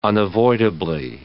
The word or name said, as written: unavoidably